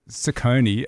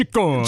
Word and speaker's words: Ciccone: I reckon I've anglicised the pronunciation. And it's because I've said Ciccone, as opposed to Ciccone. – Ciccone.